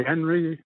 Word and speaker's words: Henry: Okay, Henry Hunter Sr. And spell it for me, Henry. – H-E-N-R-Y, Hunter, H-U-N-T-E-R, Sr., S-R.